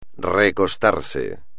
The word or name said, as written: recostarse